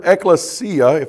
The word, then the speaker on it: ekklesia: And one of the definitions for that word, ekklesia, if I'm saying that word correctly, am I saying that correctly? Thank you, sir.